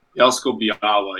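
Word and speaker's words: Bielsko-Biała: You're playing for Bielsko Biała. Is that how you pronounce it? – Bielsko Biała, yeah. The L has like a slash through it. It makes a weird sound.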